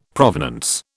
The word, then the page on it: provenance: British English